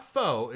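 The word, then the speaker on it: phở: It's called pho. P-H-O. Not pho. It's pronounced pho.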